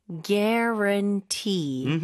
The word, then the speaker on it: guarantee: Guarantee. Guarantee. – 네. 이거 스펠링이랑 발음이랑 주의하셔야 될것 같아요. 구아렌 이렇게 될수 없죠. 스펠링이 GUA로 시작을 하지만 U가 거의 발음이 없다고 봐야 될것 같아요.